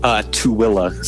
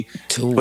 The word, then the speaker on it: Tooele: Tooele. So like, it's like, it's pronounced like T-O-W-I-L-L-A, Tooele. – But it's spelled T-O-O-E-L-E.